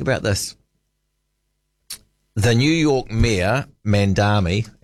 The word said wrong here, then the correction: Mamdani: and now I haven't said his name out loud before, so it might have an extra syllable in there. I should get it right. Let me just Google that up. Mamdani, Zoran Mamdani.